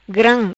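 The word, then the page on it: Gram: patua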